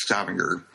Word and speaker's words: Stavanger: But I believe you start at, I guess I'll take a stab at it, but you started at Stavanger? – Stavanger. – Okay. – And when we were getting close to Stavanger, we opened the curtains to see what we would see.